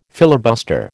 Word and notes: filibuster: American English

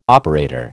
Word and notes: operator: American English

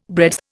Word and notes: breadth: Indian

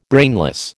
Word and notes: brainless: American English

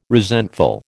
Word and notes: resentful: American English

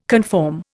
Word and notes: conform: Indian

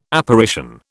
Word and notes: Apparition: British English

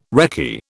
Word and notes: recce: British English

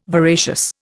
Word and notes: voracious: Indian

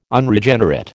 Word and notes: Unregenerate: American English

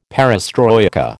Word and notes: perestroika: American English

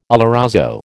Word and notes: oloroso: American English

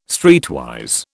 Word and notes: streetwise: British English

